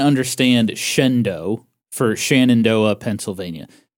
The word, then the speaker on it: Shenandoah: So in our last episode, I mentioned JCPenney opened some stores in Ely, Nevada and Shenandoah, Pennsylvania. Apparently, that's not how they're pronounced. It's actually Ely, Nevada. – Uh-huh. – And Shendo, Pennsylvania. – Okay. – Ely, I can understand. – Uh-huh. – E-L-Y.